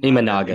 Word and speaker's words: Imanaga: And is it Imemanga? I cannot say his name. Help me out with that. – Imanaga. – Imanga, yeah.